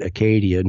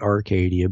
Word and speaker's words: Acadia: or the French pronounce it Acadia, not Arcadia, but it means the same thing.